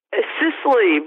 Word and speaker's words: Sisley: Sisley, but Sisley.